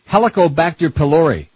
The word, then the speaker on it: Helicobacter pylori: Sulforaphane killed helicopter pilots. How do you say that? Helicobacter pylori.